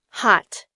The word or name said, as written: hot